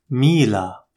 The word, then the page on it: Mila: Arabic